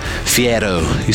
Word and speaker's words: Fiero: What about the Pontiac Fiero? Fiero. You say it like Guy Fieri.